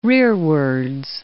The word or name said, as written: rearwards